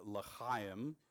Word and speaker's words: L'chaim: We are in a sermon series that we are calling L'chaim. You got to choke on that if you want to say it right.